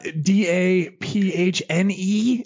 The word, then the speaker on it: Daphne: D A P H N E is her first name.